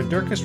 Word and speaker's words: Durkis: Sarah Durkis, Rhymes with Circus, Kelly Cameron, Wayne Pedersen, Janet Selvig, Claire Adams.